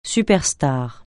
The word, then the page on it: superstar: francais